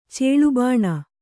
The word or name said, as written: ಚೇಳುಬಾಣ